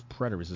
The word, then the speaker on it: preterist: Therefore, this interpretation can be seen as either partially preterist or, did I pronounce that right? Hold on. I'm very particular about knowing some of words. Like I was like, this, I don't know this word. I've never heard this word before. Preterist. What the hell is preterist? Is it preterist? Preterist? Let's see. Google don't even know how to say the word. Let's see if Collins Dictionary give us something. Do you have an audio? Let's see. Preterist. Yeah, I said it right.